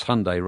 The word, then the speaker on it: Hyundai: I think we used to call it Hyundai, but it's Hyundai or Hyundai. – Hyundai. Yeah. – In America, it's Hyundai rhymes with Sunday.